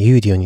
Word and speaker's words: Udio: では早速ですね、ユーディオについて話していきたいと思います。ユーディオ、これ綴りが UDIO ですね。ユーディオと読むようですね。